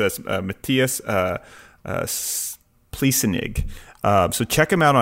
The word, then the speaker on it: Pliessnig: Uh, I guess you would say his name Pliessnig uh, P L I E S S N I G.